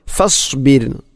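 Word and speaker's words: ഫസ്ബിർ (فاصبر): ഇവിടെ ഫസ്ബിർ ലിഹുക്മി, അധികം ആളുകളും ഫസ്ബിർ ലിഹുക്മി എന്നായിരിക്കും പറയുക. റായിനെ കനപ്പിച്ചുകൊണ്ട്, ഒരിക്കലും അങ്ങനെ പറയരുത്. ഫസ്ബിർ റായിനെ തർക്കീക്കാണ് അഥവാ നേർപ്പിച്ചുകൊണ്ട് ഉച്ചരിക്കണം ഫസ്ബിർ.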